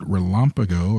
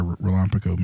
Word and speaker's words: RELAMPAGO: I hope our producers nail that, but we call it RELAMPAGO, or I hope I'm saying that correctly. But let's start off with sort of a 101 on MCSs and then transition to the broader RELAMPAGO mission. – Yeah. So, we'll get to RELAMPAGO in a second. You nailed the pronunciation, which is great.